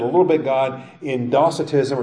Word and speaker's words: Docetism: Docetism, or some people pronounce it Docetism, from the Greek word dokeo, I seem.